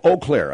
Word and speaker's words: Eau Claire: 1340 AM, and in Corbin, Kentucky, WKDP, 1330 AM, here on the world-famous Phil Hendry Show, Eau Claire.